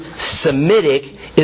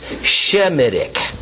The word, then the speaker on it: Semitic: Let me remind you that the term Semitic is essentially a mispronunciation. It ought to be pronounced Shemitic. Semite means from the line of Shem.